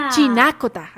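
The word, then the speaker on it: Chinácota: ¿Cómo le inspira Chinacota, ¿no? Para poder escribir este cuento. – Bueno, mira, se llama Chinacota, con la tilde en Chinacota.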